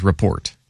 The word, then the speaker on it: report: Is it the Steelers rapport or the Steelers report? – Steelers report. – Okay, so the T is not silent.